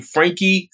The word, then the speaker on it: Frankie: My full name, Frankie, spelled with an IE, B, middle initial, and then last name, Washington.